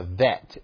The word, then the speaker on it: bet: And that's what would denote the Hebrew word bet. And if there is no dot in the center of it, then that tells you that that's a pronunciational change, and it would sound more like a V, a vet, if you will.